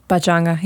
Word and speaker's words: Pachanga: how do you pronounce it? Pachanga? – Pachanga, yeah.